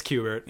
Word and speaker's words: Kubert: It's spelled with a K. I hope it's not Kubert. – But we can pretend. – It's Kubert. Yeah.